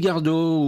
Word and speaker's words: Gardot: Mélodie Gardot, ou Gardou, comme le prononcent certains, est devenue une grande voix féminine du jazz.